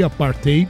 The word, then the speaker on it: apartheid: né, como é a, a, a pronúncia correta, a gente até ouviu ele falando aí, apartheid, a pronúncia correta no afrikaner, que é o idioma local.